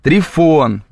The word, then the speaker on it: Трифон: Трифон. Произношение на французский манер, как трюфо, пишет нам Кейт.